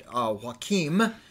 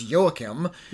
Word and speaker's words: Joachim: I've pronounced it Joachim. – Yeah. – It's Joachim. – Yeah. – Because he is Norwegian.